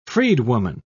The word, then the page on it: freedwoman: British English